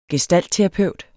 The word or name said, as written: gestaltterapeut